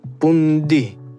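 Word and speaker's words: pundhi: Так вот, яванцы произносят просто звук «д» без «х». «Пундхи» превращается в «пунди», «пунди». «Пунди»? То есть звук «х» отсутствует полностью.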